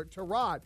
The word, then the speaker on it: Tyrod: Well, it turns out everybody's been pronouncing Tyrod's name the wrong way. It is Tyrod. According to Joel Batonio, he told a guy, Adidas something with a check mark at the end of his name, that Tyrod is actually pronounced Tyrod, and that Tyrod has been too nice to correct people. So apparently his name is Tyrod and not Tyrod.